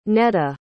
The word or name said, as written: neda